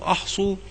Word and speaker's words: أحصوا: وقوله أحصوا بهمزة القطع من أحصى الرباعي، ومنه قوله تعالى وأحصى كل شيء عددا، أي اضبطوا لي بالعدد المعلوم جملة من ينطق بالإسلام.